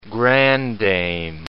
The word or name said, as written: grandame